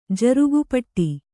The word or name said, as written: ಜರುಗುಪೀಠ